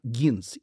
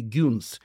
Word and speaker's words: Гинс: При рождении его назвали Раф Акива Гинс, или второе произношение этой фамилии Гюнс.